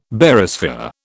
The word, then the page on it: Barysphere: British English